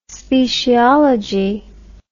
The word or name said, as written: speciology